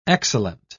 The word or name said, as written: excellent